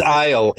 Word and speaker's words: Ehle: Ale. – I think it's Ale, E-H-L-E.